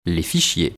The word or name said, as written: les fichiers